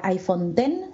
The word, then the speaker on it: iPhone X: Se escribe iPhone X, la X es el número romano 10, pero se pronuncia iPhone X según Apple.